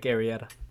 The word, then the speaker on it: Arrieta: The Cubs apparently offered Jake Arrieta. Arrieta? Is that how I say his name? – Jake Arrieta.